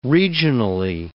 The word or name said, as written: regionally